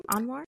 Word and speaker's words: Anwar: Anwar? Is that how you pronounce his name? Anwar? – Oh, yes. Oh, child.